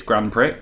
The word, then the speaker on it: Grand Prix: Oh, it was my little boy mispronounced it. It's Grand Prix, but...